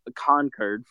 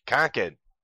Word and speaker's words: Concord: Concord. – It's, I was informed at the podcast taping today that is how you say it. It is not Concord. Like, you know, a true Englishman should say Concord.